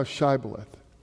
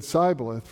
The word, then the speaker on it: Shibboleth: If they said no, then they would say to him, "Say now Shibboleth." But if they said Sibboleth, for he could not pronounce it correctly, then they seized him, and they slew him at the fords of Jordan.